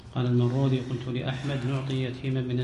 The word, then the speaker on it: المروذي: عندي المرودي. – المرودي؟ – ها؟ – المرودي؟ – لا لا لا، إن تنطق المرودي. لا، بالذال بالذال. لكن هي أحيانا يكتبونها المروذي وأحيانا المروزي، المروذي وأحيانا المروزي، لأن المنطقة اللي هو فيها ظاهر أن النسب إلى شرقيها، شرقي هذه المنطقة مروذ، وغربيها مروز الظهر.